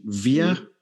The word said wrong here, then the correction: Vejle: BK. – That was a good trial. In Denmark, we say Vejle. – Vejle. – Vejle, yeah.